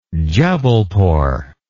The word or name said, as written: Jubbulpore